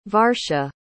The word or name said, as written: varsha